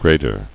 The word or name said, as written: Grader